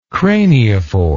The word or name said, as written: craniophore